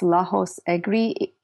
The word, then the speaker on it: Lajos Egri: Then there's this kind of more obscure one called The Art of Dramatic Writing by, I think it's pronounced Lajos Egri, E-G-R-I.